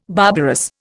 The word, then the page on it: barbarous: Indian